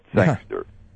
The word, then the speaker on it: Sangster: Yeah, not Sanger, but Sangster.